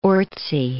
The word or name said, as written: Orczy